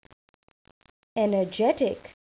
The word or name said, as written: energetic